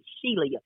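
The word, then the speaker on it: Shelia: It's S-H-E-L-I-A, Sheila. Even though we pronounce it Sheila or Sheila, but I have to do it the proper way so people know how to spell it.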